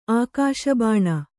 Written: ಆಕಾಶಬಾಣ

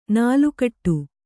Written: ನಾಲುಕಟ್ಟು

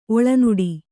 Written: ಒಳನಾಡು

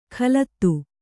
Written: ಖಲತ್ತು

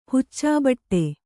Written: ಹುಚ್ಚುಬಿಡು

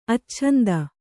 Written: ಅಚ್ಛಂದ

